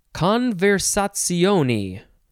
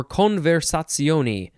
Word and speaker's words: conversazione: It is conversazioni or conversazioni. It is spelled conversazione. Conversazione or ni.